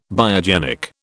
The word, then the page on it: biogenic: British English